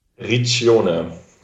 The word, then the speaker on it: Riccione: Riccione.